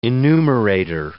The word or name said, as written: enumerator